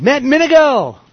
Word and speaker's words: Matt Minegell: All right, Matt Minegell.